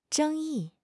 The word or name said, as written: 争议